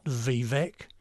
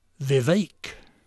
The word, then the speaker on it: Vivek: It's not Vivek. It's Vivek.